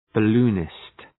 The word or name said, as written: balloonist